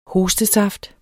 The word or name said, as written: hostesaft